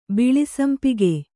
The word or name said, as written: ಬಿಳಿಸಂಪಿಗೆ